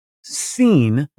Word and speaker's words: Sin: I came out to the wilderness of sin. – You should pronounce it seen. – Oh, seen. – Because it's actually, this is actually the moon god.